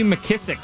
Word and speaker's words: McKissic: I got J.D. McKissic. Am I pronouncing that right? – Yes, you are. Nicely done.